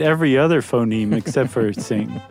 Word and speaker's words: Qing: By the way, in the draft podcast, was having trouble pronouncing Q-I-N-G dynasty. – Uh-huh. Yeah. – Q may be roughly pronounced as T-S. – Oh. – Not exactly the same. So just say Tsing next time.